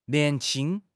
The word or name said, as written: nian2 qing1